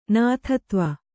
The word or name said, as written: ನಾಥತ್ವ